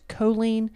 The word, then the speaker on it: choline: The topic today is choline. – Yes. – Did I say it right? – You did.